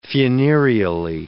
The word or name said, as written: funereally